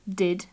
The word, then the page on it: did: English